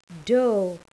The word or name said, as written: dough